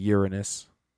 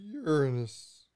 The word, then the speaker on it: Uranus: And actually, the proper way to pronounce it is Uranus, sir. – Uranus. – It's not a long A sound.